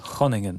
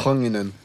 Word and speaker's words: Groningen: I'm about to drive 6 hours to Groningen. – It's pronounced Groningen. – Absolutely. – Groningen.